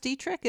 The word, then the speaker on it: Detrick: Is it pronounced Detrick in Maryland? – Yeah, it began as a farm.